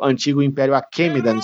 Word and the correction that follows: Aquemênida: se não me engano que é se pronuncia, não é, Gabriel? Aquênida. – Isso, Aquemênida. Aquemênida, isso.